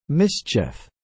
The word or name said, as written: Mschf